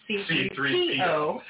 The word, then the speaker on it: C-3PO: No, C-3PO. – For C-3PO. – No, C-3PO. – For C-3PO. – There you go. – Your emphasis is on the wrong syllable. – Okay. – C-3PO.